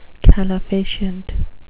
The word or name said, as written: calefacient